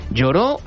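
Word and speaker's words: Lloris: Lloró no con doble L, sino con Y y sin acento en la O, porque en francés se dice Lloró.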